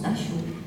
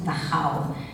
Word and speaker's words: Dachau: Dachau, échafaud, chaud, à condition bien entendu qu'on croit comme lui que ça se prononce Dachau, parce que les germanophones savent que ça se prononce Dachau, et qu'on ne peut pas faire le jeu de rime intérieure que je viens de citer.